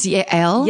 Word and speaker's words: Yael: I meant the Jewish name, Yale, Y-A-E-L. – Oh, yeah, like Yael. – Oh, is that how you pronounce it?